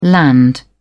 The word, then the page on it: land: British English